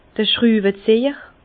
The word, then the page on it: d'r Schtrüwezejer: Bas-Rhin; Herrlisheim